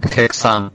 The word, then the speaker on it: Häxan: Hexan.